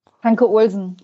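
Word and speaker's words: Hanche-Olsen: Äh, Kraus kam dann für, wie wird er ausgesprochen, Hanche Olsen? – Hanke Olsen, genau. Hanke, Hanke Olsen in der 70.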